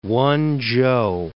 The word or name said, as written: Wen-chou